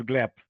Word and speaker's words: Gleb: My name is Gleb.